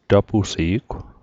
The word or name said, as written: tapusi'gw